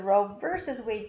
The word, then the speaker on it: Roe v. Wade: The Roe v. Wade case, or as it's pronounced, the Roe versus Wade case.